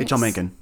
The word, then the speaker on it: Mencken: The Satanic Bible plagiarized works of H.L. Mencken. I believe that's how you say it. – Yeah, H.L. Mencken.